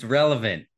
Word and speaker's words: relevant: So you got to stop saying relative. It's relevant.